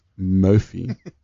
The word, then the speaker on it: Mophie: That's Mophie, pronounced Mophie battery charger, but.